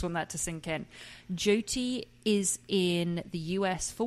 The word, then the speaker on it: Jyoti: And the synopsis was described as, hang on, I've written down how to pronounce her name because I always say Jyoti, but it's not Jyoti. It's Jyoti. Jyoti.